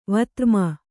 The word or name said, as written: ವತ್ರ್ಮನ